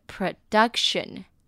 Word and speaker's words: production: produce 呢，它的 o 呢是发短音 e 的音，produce 重音在 d u c 的地方，u 发 u 的音，produce 它的名词呢是 production。 production 哦，一样发短音，重音在 d 的地方，它是指产出的意思。